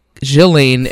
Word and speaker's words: Ghislaine: Via from Instagram said, I learned you pronounce Ghislaine as Ghislaine and not Ghislaine.